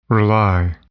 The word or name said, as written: rely